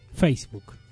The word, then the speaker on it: Facebook: De Facebook. ¿Lo dije bien? – ¿Cómo dijiste? ¿De? – Facebook. – No. – Facebook. – A ver, Facebook. – Facebook. – Facebook. – Facebook. – Sin la S tan fuerte.